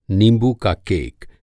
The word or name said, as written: नींबू